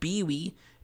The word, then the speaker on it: Biwi: The song was called Bee-Wee. B-I-W-I, which will lead to my first question. – Wait, is that how you say it?